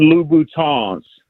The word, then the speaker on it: Louboutin: And then most importantly, so the women that listen to the show don't start tagging you on YouTube, they are Christian Louboutins. You got to pronounce it with the French.